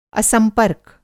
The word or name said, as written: असंपर्क